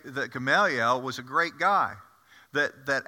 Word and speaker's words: Gamaliel: His correct way to say it is Gamaliel. Now, I grew up in the South, so it's Gamaliel.